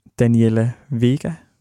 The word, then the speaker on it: Daniela Vega: Og vi lige lyttede til et nummer af Daniela Vega. Er det rigtigt udtalt? – Det er det, ja.